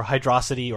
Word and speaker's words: Hydrocity: If it's a recurring song, like, you know, Green Hill or Hydrocity or Hydro City, depending on how you say it, it's basically this original 16-bit version with some, like, maybe some embellishments.